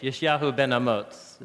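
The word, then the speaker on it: Yishyahu ben Amots (ישעיהו בן אמוץ): Isaiah ben son of Amos is how we butcher his name in English. Yishyahu ben Amots is how you say his name.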